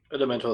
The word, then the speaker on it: Elementor: Wir arbeiten da mit diesem, wie heißt das, Elementor, wie spricht man das aus? – Elementor ist richtig.